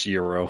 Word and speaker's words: gyro: Gyro? Is that how you pronounce it? – Gyro? I think you're right. I think it's pronounced gyro.